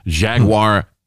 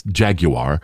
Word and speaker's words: Jaguar: The big spender also plunked down big bucks for a vintage Bentley Arnage, Jaguar. I'm sorry, I mispronounced that for our UK people. It's Jaguar, F-Pace SUV, and a Mercedes-Benz E-Class estate, along with a $6 million home.